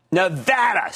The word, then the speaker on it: Nevada: Nevada. See, it's pronounced Nevada, Regina. She's always fighting with me on that.